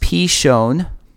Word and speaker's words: Pishon: The name of the first is, in English, it looks like it should be pronounced Pishon. It's pronounced Pishon.